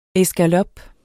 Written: escalope

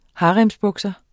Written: haremsbukser

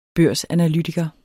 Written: børsanalytiker

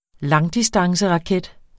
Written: langdistanceraket

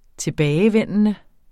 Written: tilbagevendende

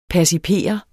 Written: percipere

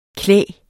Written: klæg

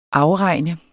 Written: afregne

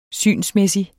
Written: SYNET

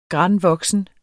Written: granvoksen

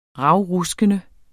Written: ravruskende

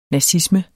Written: nazisme

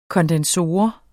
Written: kondensor

